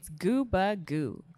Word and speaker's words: Gubagoo: Yeah, because everyone's like, is it Gooba Goo? It's Gooba Goo. – Gooba Goo. – Gooba. – Yeah, right. – Gooba like scuba.